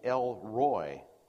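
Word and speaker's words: El Roi: And then finally, El Roy is the way it would be pronounced in English.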